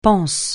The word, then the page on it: panse: francais